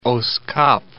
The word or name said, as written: Oos Kaap